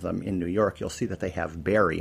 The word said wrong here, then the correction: Bari: and of course Berry. – Bari. – Bari, thank you.